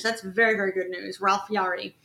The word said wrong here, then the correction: Yarl: Or Ralph Yarl? Yeah, Ralph Yarl. I'm saying his last name wrong. Ralph Yarl.